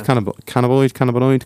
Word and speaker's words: cannabinoid: It's a high-strength cannabinoid. Can't remember how to pronounce it. – Cannabinoid. – Yeah. – Very good, very nice.